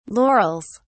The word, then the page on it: laurels: British English